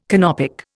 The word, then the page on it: Canopic: Indian